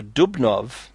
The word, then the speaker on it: Dubnow: There's a book called, 2 volumes, History of the Jews in Russia and Poland by Simon Dubnow, it's spelled Dubnow, D-U-B-N-O-W, but it's pronounced Dubnow.